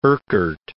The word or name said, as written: Urquhart